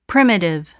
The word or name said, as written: primitive